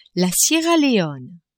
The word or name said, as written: Sierra Leone